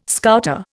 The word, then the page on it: Scouter: Indian